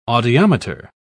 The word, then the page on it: audiometer: British English